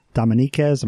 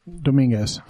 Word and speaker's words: Dominguez: As far as the white man goes, the first actual visitors were Escalante and Dominiquez. Am I saying that right? – Dominguez.